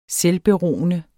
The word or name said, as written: selvberoende